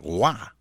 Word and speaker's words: Roy: Porque su apellido era R-O-Y. Entonces podía tranquilamente decir en español Patrick Roy, pero no, era Waugh, Patrick Roy había que pronunciar bien ese nombre.